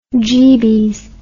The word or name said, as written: jeebies